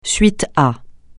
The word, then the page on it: suite à: francais